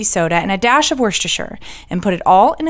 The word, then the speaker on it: Worcestershire: It might be optional and it might not, the Worcestershire, but I love Worcestershire, and yes, I'm pronouncing it correctly today.